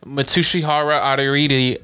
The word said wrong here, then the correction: Mitsuhiro Arita: Mitsuhiro Arita.